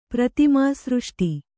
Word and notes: ಪ್ರತಿಮಾಸೃಷ್ಟಿ: Kannada

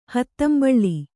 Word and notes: ಹೂದಂಬುಲ: hūdambula

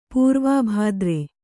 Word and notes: ಪೂರ್ವಾಭಾದ್ರೆ: pūrvābhādre

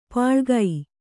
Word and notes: ಪಳಿಗೆ: Kannada

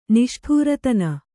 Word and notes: ನಿಷ್ಠೂರತನ: Kannada

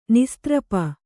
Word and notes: ನಿಸ್ತ್ರಪ: kannada

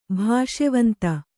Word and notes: ಭೋಷವಂತೆ: bhōṣavante; Kannada

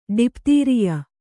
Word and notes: ಡಿಪ್ತೀರಿಯ: ḍiptīriya

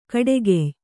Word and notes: ಕಡೆಗೆಯ್: kaḍegey; Kannada

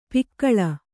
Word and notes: ಪಕಳಿ: Kannada

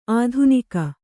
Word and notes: ಆಧುನಿಕ: Kannada

